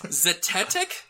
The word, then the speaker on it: Zetetic: Zetetic? Z-E-T-E-T-I-C. – Zetetic. – I got it right? – Yeah.